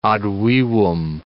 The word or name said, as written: ad vivum